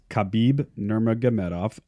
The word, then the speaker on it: Khabib Nurmagomedov: My winner is Khabib Nurmagomedov.